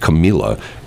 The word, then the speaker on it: Camila: I don't understand the L thing because her first name is pronounced Camila. And her last name is pronounced Cabello. And the first name, you pronounce the L's, and the second name, you don't.